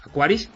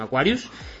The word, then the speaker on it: Aquaris: Y también tenéis las reseñas, las reviews de varios teléfonos que ya están de moda en España, como el BQ Aquaris X5 Plus. Mira, esta vez lo dije bien, Aquaris, no Aquarius.